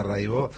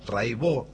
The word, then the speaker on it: Raibaut: Buenísimo, Cata Raibó. Se escribe Raibaut, métanse, búsquenla, Rai con Y, Baut con B larga. Catalina Raibaut, Raibó se dice.